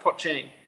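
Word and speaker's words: poitín: how do you how do you pronounce? Is it pochin? It's it's it's it's Pochin.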